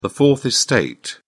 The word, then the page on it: fourth estate: British English